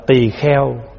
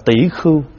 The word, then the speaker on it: tỳ kheo: Ví dụ như là mình đọc là tỳ kheo, quý ngài ở ngoài Bắc đọc là tỷ khưu, các vị tỷ khưu.